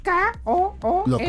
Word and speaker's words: Kool-Aid: K-O-O-L-A-I-D. – ¿Cómo se pronuncia? – Kool-Aid.